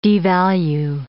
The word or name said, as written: devalue